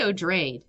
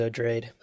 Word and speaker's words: Odrade: I say Odrade. – Odrade? – But I don't know. I'm always wrong. – The audiobook also says Odrade. – Oh, does it? Oh, my God. – Yeah. Hey, there you go. – The first time ever I pronounced something in Dune correctly.